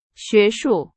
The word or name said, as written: 学术